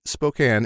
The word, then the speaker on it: Spokane: Actually, Thomas goes on to, and I didn't even see this, if you say Spokane, it's pronounced S-P-O-dash-can. – Spokane. – And not Spokane. Okay. Which is a common mistake people make.